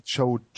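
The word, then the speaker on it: Choate: Sie haben trotz Randy äh, Quate Jode heißt er, glaube ich. Ja, Quate. Mhm habe ich. – Ich meine, er, er spricht sich Code? Randy Choad.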